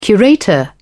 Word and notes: curator: British English

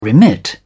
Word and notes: remit: British English

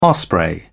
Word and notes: osprey: British English

